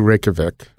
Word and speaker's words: Reykjavik: So if people are, because I've seen a lot of flights through Reykjavik, mm-hmm.